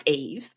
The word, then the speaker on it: Yves: You know, I spell it the masculine French way, which is Y-V-E-S. So it's pronounced Eve, and in that way it sounds identical to E-V-E, which is the feminine name.